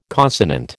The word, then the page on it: consonant: American English